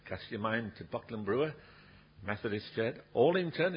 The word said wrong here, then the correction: Alwington: is that how you say it? How do you say it? Alwington. Alwington. Bucks Mills.